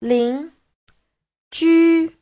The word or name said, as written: lin2ju1